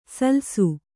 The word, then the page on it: ಸಲ್ಸು: salsu